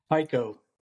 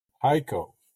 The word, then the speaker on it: Hico: Well, we've got from, how do you pronounce the name of the town you live in? – Hico. – Hico. Okay. Hico. Hico. – Yes, sir. Hico.